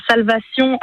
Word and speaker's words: Salvation: Ça s'appelle Salvation, donc Salvation, hein, pour le prononcer en français.